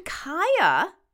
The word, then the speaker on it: Kaia: I tend to like nature names, so I love Wren, W-R-E-N, inspired by the bird. And we mentioned Kaia in one of the last episodes, K-A-I-A. It's like Maya with a K, and that's been hitting the charts too. – You could also spell it K-A-Y-A.